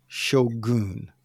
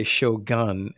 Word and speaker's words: Shogun: Well, I'm hoping that I'm pronouncing this accurately, but Shogun. I used to say Shogun until I was corrected by my wife and my brother-in-law the other night.